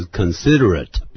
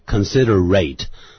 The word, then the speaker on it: considerate: 所以这边形容词 considerate，不要念成 considerate。